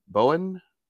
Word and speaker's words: Boen: How would you say B O E N Bowen? – Yeah.